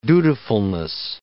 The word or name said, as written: dutifulness